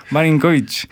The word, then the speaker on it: Marinković: Akcent chyba jest na idź, nie? – Na idź, tak. – Marinković.